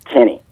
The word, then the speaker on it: Keni: And if you want to know anything about what I do, my name is spelled with an I, K-E-N-I. I think that's what's throwing Gary off. It's Kenny, K-E-N-I Thomas, not Andy Thomas.